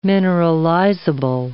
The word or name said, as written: mineralizable